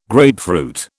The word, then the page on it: grapefruit: British English